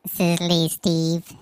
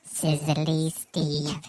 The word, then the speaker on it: Sizzly Steve: My name's Sizzly Steve, and I go to Vans. – That's your radio name? – Sizzly Steve. Emphasis on the s. – Not the izzly.